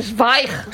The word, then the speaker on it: Zweig: Tú sabes que yo toda mi vida he estado diciendo que yo leía Stephen Zweig. – Ah, claro. Es no. Zweig.